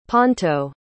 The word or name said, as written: ponto